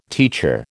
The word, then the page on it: teacher: American English